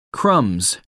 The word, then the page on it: crumbs: American English